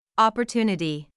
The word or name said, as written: opportunity